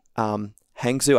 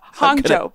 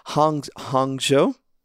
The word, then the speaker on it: Hangzhou: Nanjing, Wuxi, Hangzhou. I hope I'm pronouncing all these right. – You did a good job. Hangzhou, but close. – Okay. Hangzhou? – Yes.